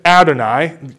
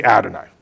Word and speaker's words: Adonai: I said Adonai. If you want me to get into the technicalities of why Yahweh is pronounced Adonai, I will, but when we read it, we say Adonai.